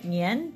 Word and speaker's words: Nian: Okay, Nian.